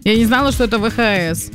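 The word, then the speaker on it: VHS: Ладно тебе, что такое ВХС? – ВХС-кассета. – ВХС, если правильно говорить.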